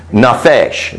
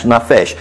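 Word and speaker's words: nafesh (נפש): In Hebrew, it's pronounced nafesh. Non-fei-shin. It's pronounced nafesh.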